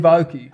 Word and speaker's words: Aoki: Steve Aoki. – Aoki, right? – Yeah.